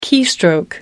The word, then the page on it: keystroke: British English